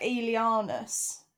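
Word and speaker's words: Aelianus: Is it like Aeolianus? – Yeah, that's right. I'm pronouncing it right? It's an E, not an A.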